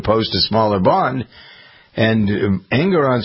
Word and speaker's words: Engoron: Engoron, is that how you pronounce his name? – Uh-huh, that's right.